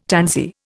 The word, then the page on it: tansy: Indian